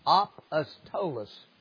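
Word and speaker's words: apostolos (ἀπόστολος): The strong, the word is not as I had always learned it pronounced, but it's apostolos.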